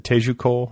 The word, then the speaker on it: Teju Cole: And you just got like a nice little write-up in The New Yorker by, is it Teju Cole?